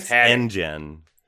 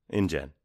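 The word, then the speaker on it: InGen: Anjean, or Anjean, however you're supposed to pronounce it. – It's Engen. It's like Jurassic Park. Engen.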